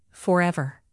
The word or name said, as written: forever